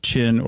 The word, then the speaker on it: Qin: So his name, we were saying Qin or something like that, Q-I-N, or Q-I-N. – Q-I-N. – It's actually pronounced Qin. – Okay. – Yeah, Q-I-N is pronounced Qin, and this is the Qin dynasty, and that becomes China.